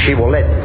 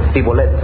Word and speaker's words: Shibolet: Y los cananitas no podían pronunciar el sonido sh, Shibolet, sino que decían Sibolet, porque así hablaban los cananitas.